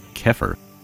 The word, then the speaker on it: KHPR: KHPR, pronounced Kefir, comes from the Egyptian word, which means to come into being, and presents programming that helps you transform yourself from a left-hand path standpoint.